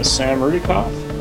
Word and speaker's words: Sam Rudykoff: I'm Sam Rudykoff, and I'm the writer and director of the short film Cruise.